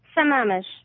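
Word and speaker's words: Sammamish: How do you say that city in Washington? – Shamamish.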